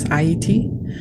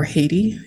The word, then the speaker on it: Haiti: And so I think to begin, my people are the people of an island nation known as Haiti, or Haiti in the English pronunciation.